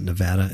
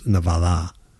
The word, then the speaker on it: Nevada: Excuse me, I keep pronouncing it Nevada. It's Nevada.